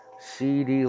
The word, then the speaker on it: CeeDee: So at number 12, they're projected to draft C.D. Lamb, wide receiver from Oklahoma. His first name is spelled C-E-E-D-E-E. Capital C-E-E, capital D-E-E. C.D.